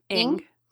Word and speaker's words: Ng: Ng.